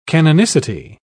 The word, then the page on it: canonicity: British English